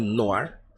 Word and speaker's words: Noir: Como, como se lê. – Noir. – Né? Mas se pronuncia no ar.